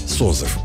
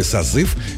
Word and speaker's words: созыв: Еще несколько десятилетий назад ударение в слове «созыв» считалось вариативным. То есть ударным мог быть как второй слог, так и первый. В середине 20 века норма изменилась.